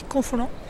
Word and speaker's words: Confolens: Comment on dit Confolens? – Confolens. – Confolens. – Oui, c'est piège. Il y a un S, mais on ne le prononce pas.